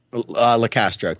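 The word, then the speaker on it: Locastro: And Tim LaCallo, how do you say his name? – LaCastro.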